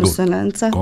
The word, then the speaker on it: Konsonanca: Da ponovim još jedanput, Udruženje građana je osnovano, ja sam rekao konsonance, ili možda nije dobar izgovor. – Konsonanca, skladnost.